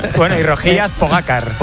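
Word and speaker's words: Pogačar: Juanma dice que es aguda, mientras que otros es drújula, así que aquí tenemos cómo se dice de verdad. – Tadej Pogacar. Tadej Pogacar. Tadej Pogacar.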